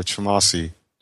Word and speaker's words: Twumasi: It's uh, Twumasi.